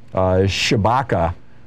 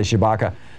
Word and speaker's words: Tshibaka: That was twice what her Trump-endorsed rival, Kelly Shibaka, I must be pronounced T-S-H-I-B-A-K-A, Kelly Shibaka, brought in, which was only $600,000.